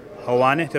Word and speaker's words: Helwani: Helwani, have I said that right? – Yes, you said it right.